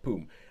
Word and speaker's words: Phume: those are pronounced with a P sound, like Poom.